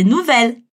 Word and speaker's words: nouvelle: De novo, j'ai de nouvelles. Aqui é legal fazer um contraste. Enquanto fille a gente pronuncia como se fosse um i comprido, nouvelle a gente precisa pronunciar os L's.